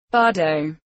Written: bardo

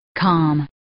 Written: calm

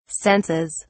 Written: senses

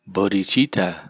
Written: bodhichitta

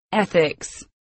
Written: ethics

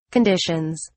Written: conditions

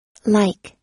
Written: like

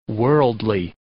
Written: worldly

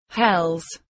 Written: hells

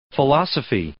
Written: philosophy

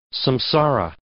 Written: samsara

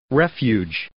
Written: refuge

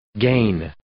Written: gain

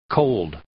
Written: cold

